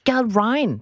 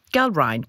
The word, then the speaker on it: Gallrein: Gal Ryan. Det er sådan, det er faktisk, det er ikke helt indlysende, hvordan det skal udtales, men jeg har slået det op. Gal Ryan. G-A-L-L-R-E-I-N.